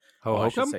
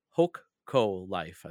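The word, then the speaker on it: Hokko Life: Ho-Hokum? Oh, no, not Ho-Hokum. Hoko Life. I think that's how I pronounce it.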